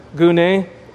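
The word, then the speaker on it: gune (γυνή): And this, by the way, is gune, which is where we get the word gyne, gune, is how it's said in the Greek.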